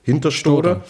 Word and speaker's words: Hinterstoder: Hinterstoder.